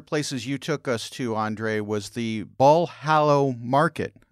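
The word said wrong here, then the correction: Bolhão: Help me pronounce that, please. – Okay, so I'm going to do it slowly because this is a toughie. Bulhão Market. – I wasn't Bulhão. Even close. I was not even close.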